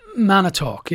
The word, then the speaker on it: Manitowoc: Is it Manitowoc? – Manitowoc, yeah.